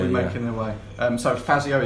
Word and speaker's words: Fazio: Oh, Fassier. Federico Fazio. I pronounced it wrong.